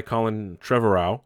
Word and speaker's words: Colin Trevorrow: So this is a short film that was supposed to be released with Hobbs and Shaw, and it was actually directed by Colin Trevorrow.